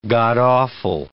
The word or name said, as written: god-awful